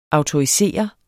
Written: autorisere

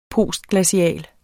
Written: postglacial

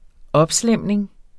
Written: opslæmning

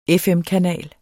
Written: FM-kanal

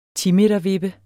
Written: timetervippe

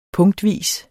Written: punktvis

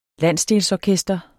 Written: landsdelsorkester

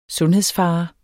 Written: sundhedsfare

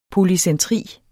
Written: polycentri

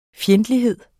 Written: fjendtlighed

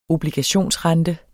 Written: obligationsrente

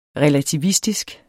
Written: relativistisk

